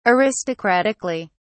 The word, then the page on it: aristocratically: British English